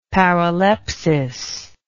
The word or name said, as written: paralepsis